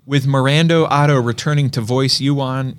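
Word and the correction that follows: Éowyn: How do you say that? – Eowyn. – Eowyn.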